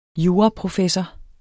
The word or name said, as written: juraprofessor